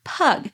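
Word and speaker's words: pygg: These pots were made with an affordable clay originally pronounced pug.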